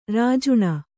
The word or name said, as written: ರಾಜಾಣ